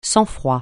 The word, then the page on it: sang-froid: francais